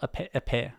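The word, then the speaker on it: épée: But I find that when I'm wandering through the world, I'll have a great EP that you can poke things with. I may have also mispronounced EP. I'm sorry. I'm terrible. Epée. I'm bad at pronouncing French stuff.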